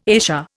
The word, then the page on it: ESA: Indian